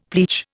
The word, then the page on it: pleach: Indian